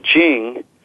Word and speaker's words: Ching: And then Ching, another confusing C-H-I-N-G pronounced with a J.